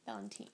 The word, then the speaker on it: Valentine: Am I saying his name wrong? – No, you're finally saying it right. – Oh, okay, good. It's not like the holiday. – Yeah. Valentine.